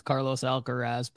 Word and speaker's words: Carlos Alcaraz: Yeah, and for those of you who are new to the show, we know his name is pronounced Carlos Alcaraz, but Dr. Crack calls him Traz.